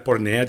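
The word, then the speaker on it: pornea (πορνεία): fornicazione pornea, pornea, dico bene? Sì, grazie Marco, perdona il mio greco.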